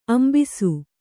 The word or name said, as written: ಅಂಭಸ